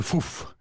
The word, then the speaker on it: fouf: Eh bien, l'animal du mardi, c'est le fouf. – Pardon? – Le fouf. Ouais. Ça s'écrit comme ça se prononce, 3F, O-U, 3F.